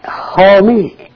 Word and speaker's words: Halmi: Homey. H-A-L-M-I.